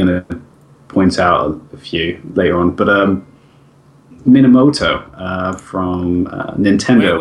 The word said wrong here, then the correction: Miyamoto: Wait, so, how did you say that? – Minamoto? – Minamoto? – Minamoto. – Miyamoto? – Yeah. From Nintendo.